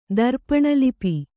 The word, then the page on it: ದರ್ಪಣಲಿಪಿ: darpaṇa lipi